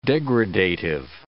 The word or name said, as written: degradative